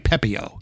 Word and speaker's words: Pepiot: By the way, I do want to mention the pitcher that I forgot how to pronounce it. It's Ryan Pepiot.